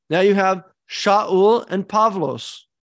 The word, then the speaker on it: Shaul: The name in Hebrew, Shaul, it's an SH. Greeks don't have an SH sound. And then two, it's got a deep glottal stop in there that Greeks don't have. Shaul, you don't have that in Greek. It doesn't sound Greek.